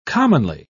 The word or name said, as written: commonly